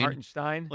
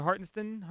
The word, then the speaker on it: Hartenstein: The way that every announcer has to go to such great lengths to pronounce the Stein part of the Hartenstein. – Hartenstein? – Like, just stop. – You mean like they want to pronounce his name correctly? – You want less enunciation? – Yeah, would you just lay off the sh part for a little bit? – So Hartenstein?